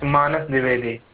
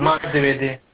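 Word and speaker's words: Manas Dwivedi: Yeah, it's Manas Divedi. – Pardon? – Manas Divedi.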